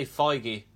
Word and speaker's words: Feige: Kevin Feige, how do you say that name? Feige. – I say Feige.